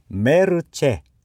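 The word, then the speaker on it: mercé: はい、メルチェ、メルチェ、はい、最後にアクセント記号ついてます。複数形もメルチェですね。慈悲、慈悲という意味の言葉です。英語ではマーシーですね。メルチェ、女性名詞です。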